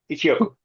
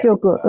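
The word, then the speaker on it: Ichioku (一億): Ichioku, yeah. Ichioku.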